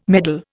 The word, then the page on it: meddle: Indian